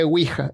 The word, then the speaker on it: Ouija: Y la Ouija deletrió, la palabra Ouija y se lee Ouija. En inglés le dicen Ouija, Ouija board, pero es Ouija. Sí. Y, en el tablero dice que se pronuncia Ouija o Ouija en, en inglés.